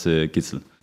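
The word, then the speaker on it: Gidsel: Ja, also in Dänemark sagen wir Matthias Gitzel. So, wir sagen nicht alle Buchstaben, so ist das in Dänemark. Aber ich verstehe alle, wenn hier in Deutschland sagen meinen Namen, aber in Dänisch heißt das Gitzel.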